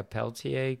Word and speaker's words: Peltier: which I think uses like a Peltier cooler. Is that how you pronounce it?